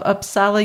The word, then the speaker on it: Uppsala: So finally, in late 2014, Karolinska officials agreed to an external investigation, and this was headed up by Dr. Bengt Gurdin of Uppsala University. And I don't know if I pronounced that correctly at all. – I think so. It's a Swedish university.